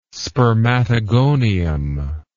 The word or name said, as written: spermatogonium